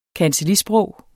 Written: kancellisprog

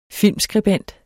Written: filmskribent

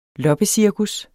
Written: loppecirkus

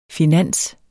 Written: finans

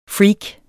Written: freak

